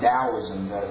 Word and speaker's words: Taoism: But don't ask me why they take that word from the Orient and then spell it with a T when it's pronounced with a D, but it's actually pronounced Taoism. Like D-O-W-I-S-M is how you pronounce it.